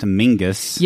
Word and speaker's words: Menzies: Well, he says that Menzies is pronounced Mingus.